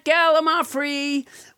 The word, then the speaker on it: gallimaufry: Let's never forget gallimaufry, which I pronounced wrong until nobody, James Harder, corrected me.